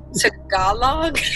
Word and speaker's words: Tagalog: And we were talking about the language spoken in the Philippines, and I pronounced it wrong. I said, like, the Girl Scout cookie, Tagalong, and one of the students was like, Tagalog? – Oh, no.